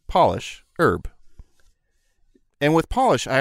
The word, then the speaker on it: Polish: They are pronounced differently when the first letter is capitalized. Job becomes Job, polish becomes Polish, and herb becomes herb.